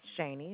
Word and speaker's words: Shaney: Sure, it's Shani, and it's spelled S-H-A-N-E-Y.